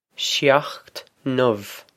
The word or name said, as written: seacht n-ubh